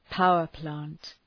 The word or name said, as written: power plant